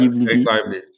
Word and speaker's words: Lively: Umarım Lively diye okunuyordur bu çocuğun ismi. – Evet, Lively.